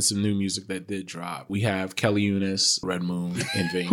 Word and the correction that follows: Uchis: Oh, you're talking about Kelly Uchis. – Nigga, you're not even saying it right. – Where are you getting the N in her last name? – I didn't say N.